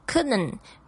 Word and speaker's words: couldn't: How's it going?、问候的时候觉得说你好吗，然后你说哦，好的不能再好了。那我们稍微注意一下这个发音 Couldn't 虽然它这后面本来是有一个 T 呢，但是你在听的时候你几乎都是听不到的 couldn't。